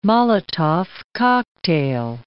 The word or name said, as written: Molotov cocktail